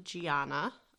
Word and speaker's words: Gianna: G-I-A-N-N-A, for anyone who doesn't know how to spell it. Some people pronounce it Gianna. We pronounce it Gianna in the Italian form.